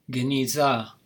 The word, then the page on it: genizah: Hebrew